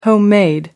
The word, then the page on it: homemade: American English